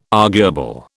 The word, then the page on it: arguable: British English